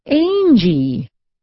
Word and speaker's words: Angie: If you want to be short for Angel, it should be Angie, with a long A." – Nicole's lips had twisted into a mean-looking smile. – "To rhyme with mangy."